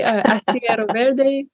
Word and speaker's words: Astillero Verde: So hopefully I say it okay. Astillero Verde, that's how they say it.